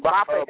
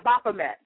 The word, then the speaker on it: Baphomet: Yeah, I know I can't pronounce his name. – Baphomet. – Yeah, Baphomet, yes.